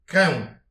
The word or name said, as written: krenv